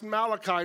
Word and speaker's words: Malachi: And it's pronounced Malachi, not Malachi, although that would be more fun in my opinion.